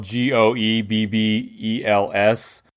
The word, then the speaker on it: Goebbels: We always so there's no R. It, you know, Goebbels is spelled G O E B B E L S go Goebbels.